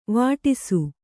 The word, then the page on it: ವೇಡಿಸು: vēḍisu